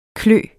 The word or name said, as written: klø